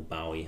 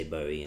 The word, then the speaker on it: Bowie: And then I thought I really need to listen to more Bowie or Bowie, however you want to pronounce it. I think the Brits say Bowie, and he was British, so there we are.